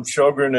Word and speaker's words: Shogren: Shogren is a Swedish name. The original was S-J-O with umlauts, Sjögren.